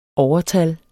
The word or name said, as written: OVERTALLET